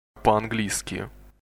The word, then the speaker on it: По-английски: Listen again. Be sure to put emphasis on the p sound. – По-английски.